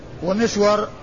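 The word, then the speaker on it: مِسْوَر: مسور ومسور، مسور بضم الميم وفتح السين والواو المشددة، ومسور بالميم المكسورة والسين الساكنة والواو المفتوحة.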